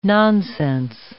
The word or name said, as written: nonsense